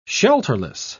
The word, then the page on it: shelterless: British English